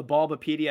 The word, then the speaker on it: Bulbapedia: Yeah, for me, it's Zach Charbonnet, and I pulled up the Bulbapedia. I think that's the right way to say it. Yeah, beautiful.